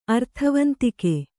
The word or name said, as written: ಅರ್ಥವಂತಿಕೆ